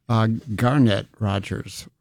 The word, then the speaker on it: Garnett Rogers: I think I've been putting the accent on wrong syllable. Garnett Rogers.